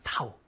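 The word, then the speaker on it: 타우 (τ): 그리스어 14번째 글자거든요. 타우예요, 타우. 타우라고 읽기도 하고, 돌림임이라고 읽기도 하고, 여러분 내년에 대학에 가면 토크라고 읽기도 합니다.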